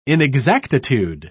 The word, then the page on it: inexactitude: British English